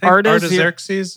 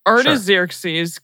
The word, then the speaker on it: Artaxerxes: how do you say Artaxerxes? – Artaxerxes is what we said. Arta. Yeah. Artaxerxes, king of Persia.